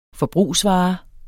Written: forbrugsvare

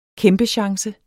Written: kæmpechance

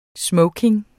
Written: smoking

